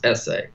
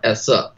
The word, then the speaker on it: esse: Having existence, which is, he calls esse, or essa, depending on your pronunciation.